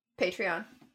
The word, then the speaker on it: Patreon: You can also be part of our um patron. I always say that wrong. Patreon.